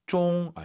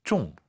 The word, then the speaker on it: 中: 그렇죠. 쫑 아니고 쫑. 맞추는 거니까 사성입니다.